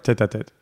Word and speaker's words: Tête à Tête: Did I say that right? – Tête à Tête, yeah.